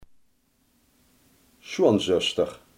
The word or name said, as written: schoonzus